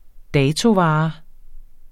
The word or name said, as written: datovare